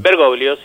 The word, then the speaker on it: Bergoglio: Bergoglio, sí.